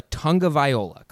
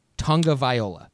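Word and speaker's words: Tagovailoa: So it's Tua Tonga Viola, correct? Tonga Viola.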